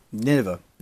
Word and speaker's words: Nineveh: Nineveh.